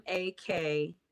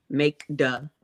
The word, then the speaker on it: Makeda: MakeDa. Makeda.